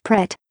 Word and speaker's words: Pret: Make sure you say the T, and don't say manger. And if all else fails, just go with Pret, rhymes with Brett.